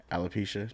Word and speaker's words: alopecia: And so Jada has a bald head because she has alopecia.